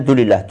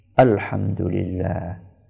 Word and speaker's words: Alhamdulillah: Banyak orang ketika membaca itu tidak sempurna. Karena pengen cepat selesai, maka dia bacanya Dulillah, Dulillah, Dulillah. Apa itu Dulillah?